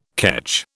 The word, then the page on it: ketch: British English